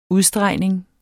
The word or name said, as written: udstregning